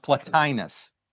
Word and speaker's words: Plotinus: It's pronounced Plotinus, P-L-O-T-I-N-U-S, Plotinus.